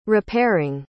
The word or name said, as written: repairing